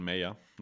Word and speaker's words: Mayer: Michael Meyer. Genau, also Michael Mayer. Natürlich. Mayer, ja, natürlich. Englisch ausgesprochen, ja.